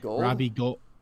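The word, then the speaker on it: Gould: It's Robbie Gold. Yeah. Yeah. – But it's spelled like there's like a U in it. – His last name is like Ghoul, like yeah. A ghoul. Yeah.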